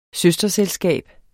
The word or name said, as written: søsterselskab